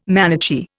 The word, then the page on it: Manichee: Indian